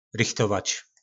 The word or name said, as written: rychtovac